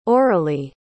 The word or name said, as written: aurelie